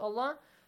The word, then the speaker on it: الله: If you have a fatha sound or a kasra sound before the word Allah, the letter lam in the word Allah is pronounced heavily with an A sound.